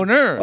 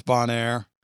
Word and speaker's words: Bonaire: Boner. – Please save us. – And she is there. – Brittany, tell him that's not how you pronounce Bonaire.